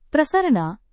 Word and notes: prasarana: Indonesian

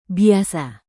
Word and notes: biasa: indonesian

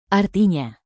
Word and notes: artinya: indonesian

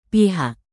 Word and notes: pihak: indonesian

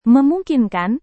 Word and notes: memungkinkan: Indonesian

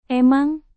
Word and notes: emang: indonesian

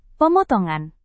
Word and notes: pemotongan: Indonesian

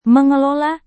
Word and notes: mengelola: Indonesian

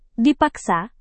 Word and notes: dipaksa: Indonesian

